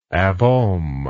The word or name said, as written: abohm